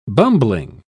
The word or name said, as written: bumbling